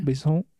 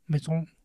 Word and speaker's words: Besson: De Luc Besson, o Besson, o Besson, no sé cómo se pronuncia. Besson. – Besson.